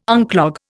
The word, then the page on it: unclog: Indian